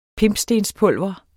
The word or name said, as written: pimpstenspulver